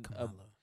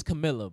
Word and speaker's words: Kamala: Even the way that you pronounce her name, like, bro, that's not even Kamala. It's Camilla, bro. – Oh, Camilla. – No, it's Kamala. That's how you pronounce it.